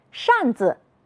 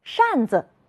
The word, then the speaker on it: 扇子: 扇子，是的，耷拉，竖着。你发现了什么？有的同学说，老师，后一个字都要读成轻声。你们真会观察，我们再来读一读。扇子，是的，耷拉，竖着。